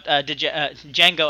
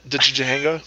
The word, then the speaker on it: Django: I did, like, he did that joke, like, he was talking about Django. I always pronounce the D, even when I'm not reading it. – Django? – Yeah.